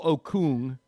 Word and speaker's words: Okung: He said there's a former Seahawks lineman, Russell Okung. I'm pronouncing that right. – You're right.